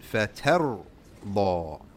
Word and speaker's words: فَتَرْضَى: This ra, it will be pronounced with tafkheem. Why? Is it have a kasra? No. It has a sukoon. Is the previous letter a kasra? No. Okay? So you pronounce it فَتَرْضَى.